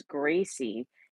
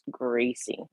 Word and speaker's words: Greycie: And the gray cat, her name is Gracie. And we spell it with G-R-E-Y-C-I-E. So it's Gracie, like her color, because she's so cute.